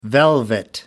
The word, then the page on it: velvet: American English